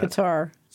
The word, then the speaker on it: Qatar: And this story is about Qatar, Q-A-T-A-R. Some people pronounce it Qatar. I can't bring myself to do that. – Qatar. – So it's Qatar it is, okay? Rhymes with guitar.